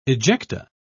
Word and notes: ejecta: British English

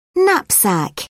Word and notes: knapsack: British English

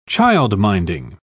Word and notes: childminding: British English

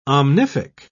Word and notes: omnific: British English

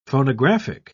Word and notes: phonographic: British English

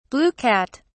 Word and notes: blue cat: British English

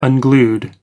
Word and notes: unglued: American English

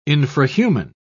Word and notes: infrahuman: British English

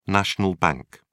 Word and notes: national bank: British English

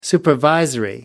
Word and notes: supervisory: American English

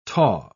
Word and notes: taw: British English

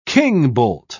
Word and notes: kingbolt: British English

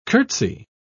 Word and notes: curtsey: British English

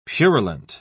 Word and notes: purulent: British English

